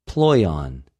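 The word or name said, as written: πλοιον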